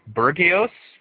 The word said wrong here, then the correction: bourgeois: which basically is a word for the ruling elite and owners of the main, what was that? – It's bourgeois. – Bourgeois. Okay. Thank you, Paradigm.